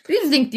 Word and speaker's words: Riesling: Riesling diye okunuyor. Bunda böyle bir şey yok. Riesling Riesling. Riesling evet yani bir öncekilerdeki gibi Fransızlar şöyle okuyor, Almanlar böyle okuyor değil direkt Riesling.